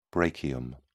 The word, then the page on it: brachium: British English